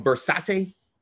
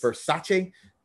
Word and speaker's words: Verzache: So this artist is called Verzache. – Nice. – Verzache. And it's spelled V-E-R-Z-A-C-H-E.